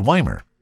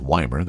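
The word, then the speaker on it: Ymir: In fact, just a little south of Nelson, near a town called Wymer. Again, a cute little town if you've never been there. That's Y-M-I-R, and it's Wymer. That's how you say it.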